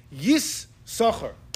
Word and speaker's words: יששכר: You pronounce both chins, but you know how you pronounce it? Yis-socher.